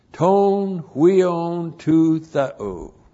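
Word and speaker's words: Ton huion tu thao (τὸν υἱὸν τοῦ θεοῦ): Ton huion tu thao.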